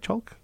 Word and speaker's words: Chalk: U of R's Connor Chalk. Am I pronouncing his name right? Yep. Is that right, Chalk? Okay, I was going to say I've said it enough times.